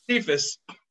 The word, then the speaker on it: Cephas: Sepsis, whatever his name, you probably know it. What's the guy from Detroit? – Quintez Cephas. – Cephas. You're the pronunciation guy.